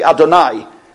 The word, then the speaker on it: Adonai: We don't ever pronounce that. We pronounce it actually Adonai.